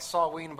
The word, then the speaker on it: Samhain: I know it doesn't look like Samhain, by the way, but that's how it's pronounced, Samhain, or very close to it, Samhain.